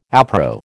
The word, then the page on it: appro: American English